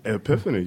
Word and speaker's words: Epiphany: He is with, you have any idea how to pronounce this? Epiphany? – Epiphany. I'm going to try to say it phonetically. Am I in the ballpark? – Hey, Epiphany, yeah. You got it right.